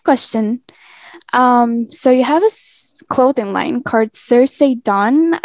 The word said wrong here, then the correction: Cerise Dawn: I think it's pronounced right. – It's Cerise Dawn, yes. – Cerise Dawn, okay.